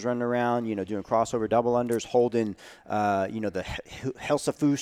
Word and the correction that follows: Husafell: stone, or I'm sorry, mispronouncing it, Husafell stone, and, you know, running up steps in Madison, Wisconsin.